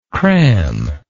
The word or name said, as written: crammingly